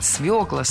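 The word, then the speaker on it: свёкла: Правильный вариант – свекла, свеклу, из свеклы. В этом слове должна быть «ё», и нельзя ее заменять, и переносить ударение на окончание тоже не следует.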